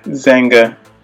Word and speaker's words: Xanga: Yeah, Zanga.